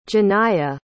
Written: Janiah

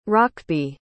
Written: hrocby

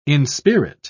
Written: inspirit

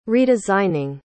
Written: redesigning

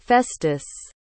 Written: festus

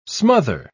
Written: smother

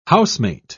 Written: housemate

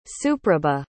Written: suprabha

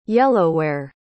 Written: yellowware

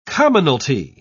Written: commonality

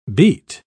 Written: beet

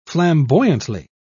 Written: flamboyantly

